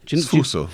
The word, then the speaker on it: sfuso: Yeah, Matteo in Sofuso. I hope I'm saying that correctly. Fuso. – Sfuso.